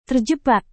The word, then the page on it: terjebak: indonesian